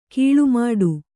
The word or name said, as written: ಕೀಳ್ಮಾಡು